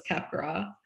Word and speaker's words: Capgras: It is French in origin, so it's cap gras.